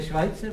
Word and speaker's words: Schweitzer: No to se vyslovuje Švajce. – Co? – To je Švajcer, právě.